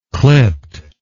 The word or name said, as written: clipt